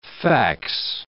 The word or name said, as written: fax